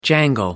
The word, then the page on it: jangle: American English